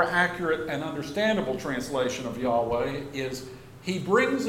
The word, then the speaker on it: Yahweh: We've heard YHWH, which is usually pronounced Yahweh.